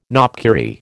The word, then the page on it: knopkierie: American English